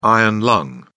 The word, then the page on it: iron lung: British English